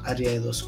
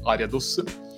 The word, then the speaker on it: Ariados: Allora, eh, io dico Ariados intanto perché sono cresciuto appunto con questa pronuncia, ormai per me è quella canonica, quindi Ariados.